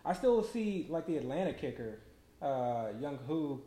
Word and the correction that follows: Younghoe Koo: Young Younghoe Koo.